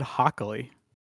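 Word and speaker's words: Hochuli: How do you say it? – It's Ed Hockley. – I threw an extra syllable in there. You're right. – You threw an N in there.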